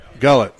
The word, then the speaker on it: Gullett: Gillett or Gullett? – Gullett? Gullett, okay.